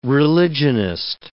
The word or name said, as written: religionist